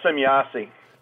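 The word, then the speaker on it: Semjase: And as a contactee, you had contact with Semyasi. Am I saying the name right? – Yeah, Semyasi. – Semyasi. – That's a German pronunciation.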